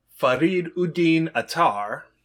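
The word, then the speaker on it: Farid ud-Din Attar: So there was a 12th century Sufi poem by Farid Uddin Attar. – Well done on the pronunciation.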